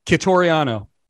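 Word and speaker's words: Quitoriano: how do you pronounce his name? Tiorentino? – Tegan Catoriano. – What is it? – Catoriano. Yeah.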